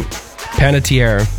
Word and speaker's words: Panettiere: Panettiere.